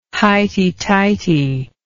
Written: highty-tighty

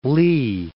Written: Fort Lee

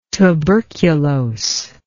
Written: tuberculose

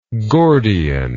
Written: Gordian

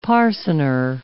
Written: parcener

